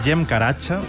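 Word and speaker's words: Cem Karaca: Este es Cem Caracha, que se escribe Cem Caracas, si lo queréis buscar.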